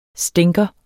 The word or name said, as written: stinker